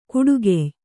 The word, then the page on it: ಕುಡುಗೆಯ್: kuḍugey